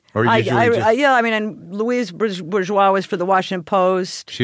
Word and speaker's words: Louise Bourgeois: Is that how you say her name? – Yeah, Louise Bourgeois.